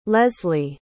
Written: lezlie

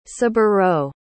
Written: saburou

